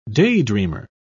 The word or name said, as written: daydreamer